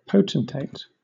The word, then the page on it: potentate: British English